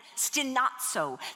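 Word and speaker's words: stenazo (στενάζω): Every one of those are forms of the word, the Greek word stenazo, S-T-E-N-A-Z-O, and it's a long O, stenazo.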